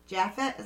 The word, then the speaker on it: Japhet: Mother Leeds has been identified by some as Deborah Leeds on grounds that Deborah Leeds' husband, Japhet is how I'm going to pronounce it, Leeds, because he's not Spanish, so I'm just going to say Japhet Leeds, named 12 children in the will he wrote during 1736, which is compatible with the legend.